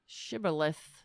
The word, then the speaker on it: shibboleth: Shibboleth.